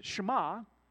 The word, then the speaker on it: Shema: The Hebrew word for hear is Shema. It's pronounced a little bit different for that, but in English, that's what we would say.